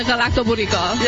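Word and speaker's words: Galaktoboureko: And she said, if you say garlic bread to go really quickly, like garlic bread to go, it sounds very similar to the Galactoburico.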